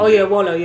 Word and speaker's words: Oyelowo: Oyelowo, yeah, it's a Nigerian surname.